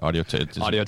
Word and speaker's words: Audio Technica: Audio Technica.